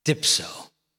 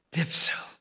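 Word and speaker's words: dipso (διψῶ): Two syllables in the Greek, dipso. He's been on the cross and you can barely hear him. Dipso.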